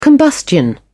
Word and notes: combustion: British English

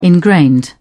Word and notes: ingrained: British English

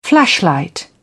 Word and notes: flashlight: British English

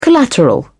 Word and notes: collateral: British English

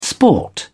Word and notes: sport: British English

